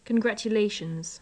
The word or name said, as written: congratulations